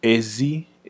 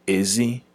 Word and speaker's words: ezi: Well, in general, the difference is mostly tone. – Okay. – Ezi is how we would use, is how we would pronounce it for outside. – Mm-hmm. – Ezi is how the tone will go for the word for pig. – Okay. – So you use ezi versus ezi.